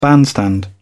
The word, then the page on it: bandstand: British English